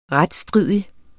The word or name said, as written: retsstridig